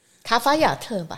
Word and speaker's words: 卡法亞特: 卡特亚自然保护区。 – 卡法亚特吧？ – 哎，卡法亚特，对啊，我怎么会念成卡特亚吧？